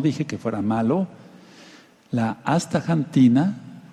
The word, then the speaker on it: astaxantina: y si quieren se las deletreo: A-S-T-A, ahí dice hasta, pero sigue la palabra corrida, X-A-N-T-I-N-A, astaxantina. Se podría pronunciar como si dijéramos México o México, pero lo más correcto es decir astaxantina.